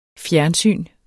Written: fjernsyn

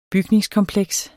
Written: bygningskompleks

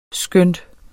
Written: skønt

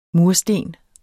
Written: mursten

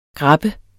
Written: GRABBES

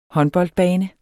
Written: håndboldbane

